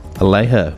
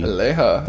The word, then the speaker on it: Aleja: This next track is Ether, the Headless Horseman remix by Elijah. – Aleja, maybe. – Aleja. – Aleja Sanchez. Aleja. If that is a Spanish name and the J is pronounced as a H.